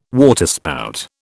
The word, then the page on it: waterspout: British English